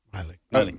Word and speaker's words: Milley: How do you pronounce it? Miley? – Miley. – Miley. – Okay. – I think it's Miley.